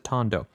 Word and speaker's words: Tondo: An album by Tondo, I think he pronounced, or Tondo, actually he says. He says it Tondo, which is short for Tony.